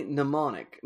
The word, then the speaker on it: mnemonic: Johnny Mnemonic. Is it mnemonic? Yeah, so the M is silent, right? – I love a silent M.